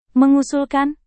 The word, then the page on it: mengusulkan: Indonesian